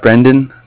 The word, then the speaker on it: Brendan: It's Brendan, spelled B-R-E-N-D-A-N.